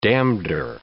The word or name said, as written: damneder